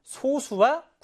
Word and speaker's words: 소수: 정확한 발음은 소수입니다. 소수. 우리 0.1, 0.2 이런 소수와 구분을 짓자는 의도에서 발음상 소수라고 부르는데요.